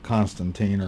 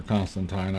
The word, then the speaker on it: Constantine: Well, I'll answer this question first by taking a look at Constantine or Constantine. I've heard it pronounced both ways.